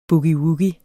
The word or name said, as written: boogie-woogie